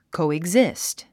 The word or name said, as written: coexist